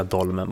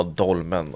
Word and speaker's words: Dolmen: Men dolmen, alltså hur uttalar man den? Dolmen eller dolmen eller? Jag vet inte. Jag tycker kör det svenska, dolmen bara. Ja, dolmen.